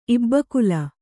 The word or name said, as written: ಇಬ್ಬಕುಲ